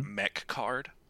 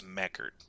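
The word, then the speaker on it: Mecard: It's called Mechard, but I want to pronounce it Mechcard, but it's Mechard. Mechcard.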